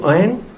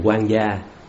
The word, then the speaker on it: oán: Người do oán mà, cái chữ đó đúng là chữ oán, nhưng mà người gia người Việt mình đọc làm sao thành quan gia, quan nha, chứ không phải là oán, oán mới đúng.